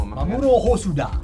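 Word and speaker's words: Mamoru Hosoda: Scusa Smith, come si dice Mamuro Hosuda? Noi lo diciamo Mamuro Hosuda. – Ah, ecco. Mamuro Hosuda.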